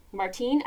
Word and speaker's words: Martine: is Arkady Martin. Um, Martine, I think it actually might be Martine. I might have mispronounced that.